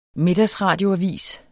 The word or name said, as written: middagsradioavis